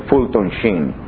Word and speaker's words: Fulton Sheen: Se pronuncia en inglés Fulton Sheen.